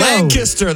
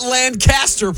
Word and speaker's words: Lancaster: Lancaster, that's right. Not Lancaster, Producer Hunter. – It's Lancaster. You got to say it like your country.